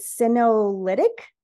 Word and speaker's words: senolytic: Is it senolytic? – Yes. – Okay. – Yes. – So what are, yeah. All right. So what are senolytic therapies and what's their purpose? – So senolytic therapies are either their compounds,